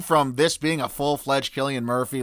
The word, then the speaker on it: Cillian Murphy: And now Cillian Murphy, whose name I'm going to pronounce correctly, finally.